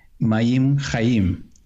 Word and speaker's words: Mayim Chaim: When we say this in Hebrew, running waters is pronounced Mayim Chaim. It's basically living waters.